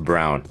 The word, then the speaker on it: Braun: And I know it's pronounced Brown, but I refuse to say it that way because it's spelled B-R-A-U-N.